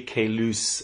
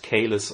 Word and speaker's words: Caylus: This is actually the official pronunciation of this name. I've checked it with the French Foreign Institute. It's really Caylus and not Caylus or Kailus.